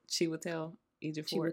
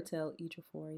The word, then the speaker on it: Chiwetel Ejiofor: Chiwetel Ejiofor. – Chiwetel Ejiofor, yes.